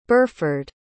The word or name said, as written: berford